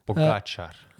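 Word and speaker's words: Pogačar: Pogacar.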